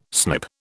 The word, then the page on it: snip: British English